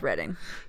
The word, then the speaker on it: Reading: Yeah, because the British pronounce everything weird, it's Reading.